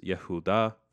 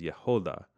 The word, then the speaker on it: Yehuda: But according to the Masoretes, it is pronounced differently, suggesting it is some sort of passive participle, meaning praised one, rendering Judah as the one who is praised. So it's Yehuda versus Yehoda.